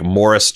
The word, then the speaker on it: Maurice: The English version of Maurice is pronounced like Morris Chestnut's name.